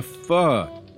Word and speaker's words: pho: No one here, Vietnamese speaking or English, pronounces pho with a long O. Everyone pronounces the soup with a pho sound.